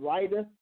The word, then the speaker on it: Riyadh: And Rida, R I Y A D H.